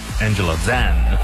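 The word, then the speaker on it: Angela Zhang: 她自己发音 Angela Zan。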